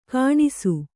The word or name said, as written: ಗೊಣಸು